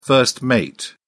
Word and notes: first mate: British English

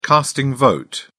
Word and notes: casting vote: British English

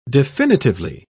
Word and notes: definitively: British English

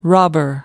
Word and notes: robber: American English

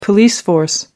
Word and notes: police force: American English

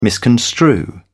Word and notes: misconstrue: British English

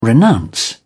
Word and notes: renounce: British English

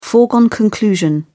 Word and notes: foregone conclusion: British English

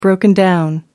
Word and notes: broken-down: American English

